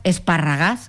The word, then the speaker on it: esparragás: las espinacas esparragás, que dicen ellos, yo dije esparragadas, ¿no? Se escribe, no, esparragás.